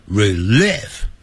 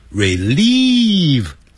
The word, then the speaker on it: relieve: To 可以加上去也对，不加也对，所以 help relieve 可以帮助疏解， relieve 代表疏解，不要念成 relieve， relieve 长元音 relieve eating my stress 就是我的紧张情绪。